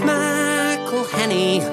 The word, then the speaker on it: McElhenney: Pronouncing all those N's and E's and H's can perplex them. So here's a little birthday gift from all your mates in Wrexham. It's McElhenney, McElhenney.